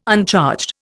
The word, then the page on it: uncharged: Indian